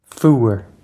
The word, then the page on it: fuar: foo-urr